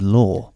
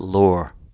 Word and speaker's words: Loar: L-O-A-R. Like, it's pronounced like raw, but... – Well, it's not. It's pronounced that way if you're... I would pronounce it Lore.